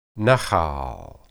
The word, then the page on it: nahal: hebrew